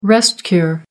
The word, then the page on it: rest-cure: American English